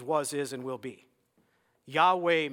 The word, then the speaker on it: Yahweh: It's coming from the German Yah, It's the J. They use J instead of Y. So people converted that to the English Jehovah, even though it's Yahweh is the correct pronunciation of the name of God.